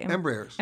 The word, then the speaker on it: Embraer: How do you say embraer? Embraers? Embraer. Yeah. Mm-hmm.